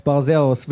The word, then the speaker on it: Barzeros: Yes, Barzeros.